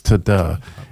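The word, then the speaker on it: tada (תודה): I mean, literally, if you pronounce it, it's tada.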